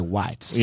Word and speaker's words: White: That's technically in White, Georgia, which, yes, is part of Bartow County. So there's, or as the locals say, Whites.